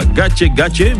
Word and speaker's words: Gake Gake: Oui, ça se prononce comme ça, Gache Gache, mais ça s'écrit Gake Gake.